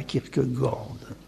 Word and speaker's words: Kierkegaard: Je vais distinguer, en contrepoint de Kierkegaard, puisqu'on prononce comme cela Kierkegaard, trois stades du messianisme, en me rappelant de manière décalée les trois stades de l'existence qu'il a distingué.